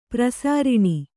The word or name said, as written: ಪ್ರಸಾರಿಣಿ